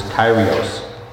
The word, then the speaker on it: Kyrios: I looked up the Greek word for Lord here, and forgive me if the pronunciation is not correct, but I believe it is pronounced as Kyrios. And another place where this word is, same word is used, is in Genesis chapter 2, verse 7.